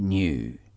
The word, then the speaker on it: knew: I knew. Knew s'écrit K-N-E-W. I knew. C'est la même prononciation que le mot new qui veut dire nouveau et qui s'écrit N-E-W sans K. La prononciation est la même.